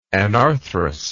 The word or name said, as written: anarthrousness